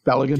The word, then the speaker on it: Balogun: I think he's getting a little frustrated the newly um, how do you pronounce his name? Uh, Ball uh, Balogun. Yeah, Balogun.